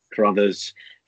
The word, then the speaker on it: Crothers: It is Charlie Crothers. Say it like brothers, just chuck the C on the front.